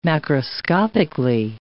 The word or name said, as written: macroscopically